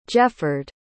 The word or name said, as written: jefford